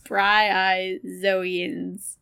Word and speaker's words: bryozoans: Their skin shedding is not only helpful for growth, but it also rids olive sea snakes of algae, barnacles, and bryozoans. Whatever those things are. I even, in parentheses, wrote out how to say it. Bryozoans.